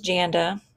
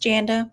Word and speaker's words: Janda: So as I mentioned, my name is Janda. It's like panda with a J.